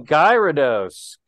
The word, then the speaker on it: Gyarados: No, it's actually Gyarados.